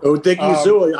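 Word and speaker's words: Odighizuwa: Did I say that right? You're the pronunciation guy. – Odigizua, I think, is correct.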